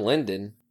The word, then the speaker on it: Lydon: It's pronounced Lydon? Wait, are you serious? – You fucking met him. You walked away from meeting John Lydon thinking his name was John Lydon, you fuck.